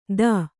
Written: ಥೂ

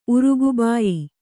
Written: ಉರುಗುಬಾಯಿ

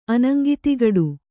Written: ಅನಂಗಿತಿಗಡು

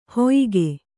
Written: ಹೊಯಿಗೆ

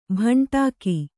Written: ಭಾಂಡಿಕ

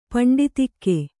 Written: ಪಿಂಡೀತಕ

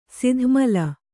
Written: ಸದಮಲ

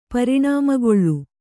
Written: ಪರಿಣಾಮಗೊಳ್ಳು

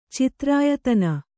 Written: ಚಿತ್ರಾಯತನ

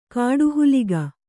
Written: ಕಾಡುಹುಲಿಗ

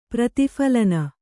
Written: ಪ್ರತಿಫಲನ

